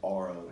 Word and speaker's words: Auro: We don't sell a ton of it here, but it's called the brand is Aro, and it's spelled A-U-R-O.